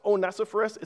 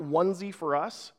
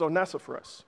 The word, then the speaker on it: Onesiphorus: It's pronounced Onesiphorus. It's not onesie for us. It looks like it could be onesie for us, but it's Onesiphorus.